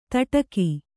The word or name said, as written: ತಟಕಿ